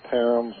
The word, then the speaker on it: Parham: Parham, which is spelled P-A-R-H-A-M, where I came from. We call that Parham. Some places it's called Parham, and maybe other places something else.